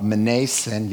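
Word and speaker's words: Mnason: Some of the disciples from Caesarea accompanied us and brought us to the home of Menasen. Yes, you do pronounce the M in that case, where we were to stay.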